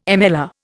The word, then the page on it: MLR: Indian